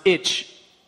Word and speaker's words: ITCZ: or which is affectionately pronounced itch by mariners.